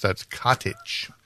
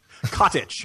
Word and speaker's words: Katic: If you're going to be on a show that I'm going to be on, you better pronounce her last name properly, or I'm coming for you. That's Katic. – Cottage, yeah. Okay, as in I have a nice cottage in the woods.